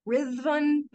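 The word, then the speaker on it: Ruthven: I pronounce it Lord Rhythvan, but it's spelled in a way that you'll find like a million different pronunciations of it,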